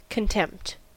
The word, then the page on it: contempt: /kənˈtɛmpt/; American English